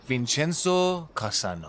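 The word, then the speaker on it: Vincenzo Cassano: اسممو مثل یه اسم انگلیسی تلفظ کنید. وینچنزو کاسانو.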